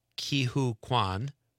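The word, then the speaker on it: Ke Huy Quan: I know I'm going to say his name right. Ki-Hoo Kwan, who we talked about last night, who was in The Goonies.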